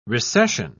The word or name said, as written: recession